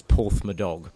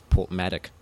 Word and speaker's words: Porthmadog: And this lovely little town, and we spent 3 days there pronouncing the name of the town as Porthmadog. Because that's how it's spelt. And it was not until the last day, as we were leaving, someone corrected us and told us it was Portmadoc.